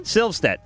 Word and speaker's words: Silvstedt: All right, let me try to pronounce Victoria's last name. Silvstedt. – You got it! Woo! – Yes. – You're like one of the few that got it right the first time.